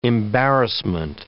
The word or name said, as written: embarrassment